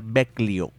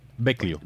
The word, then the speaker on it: Vecchio: Eh, perdón, se, pronuncia Vecchio Vecchio Vecchio, lo H en italiano suena como, que, como K.